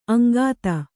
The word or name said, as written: ಅಂಗತ